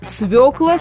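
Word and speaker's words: свёкла: При этом то и дело эту самую свеклу обижают, называя свеклой. А это на деле грубейшая речевая ошибка. Поэтому ударение будет таким. Свекла, свеклый, свекольный, много свекол.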